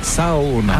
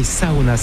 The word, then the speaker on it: sauna: En fait, on prononce chaque lettre, donc c'est Sauna. – Ah oui, Sauna, c'est vrai qu'on prononce chaque lettre.